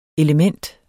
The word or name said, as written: element